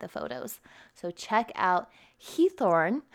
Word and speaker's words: Giethoorn: So it's Heathorn. Oh my gosh, I've watched so many different YouTube videos to try and practice that. Heathorn. It's spelled G-I-E-T-H-O-O-R-N, and I know I'm probably butchering that, so my Dutch friends, please forgive me, but it is in English, we would probably say it like Giethoorn, I guess, but it's in the Netherlands, and this place has no roads or any modern transportation at all, only canals and 176 bridges.